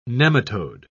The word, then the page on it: nematode: British English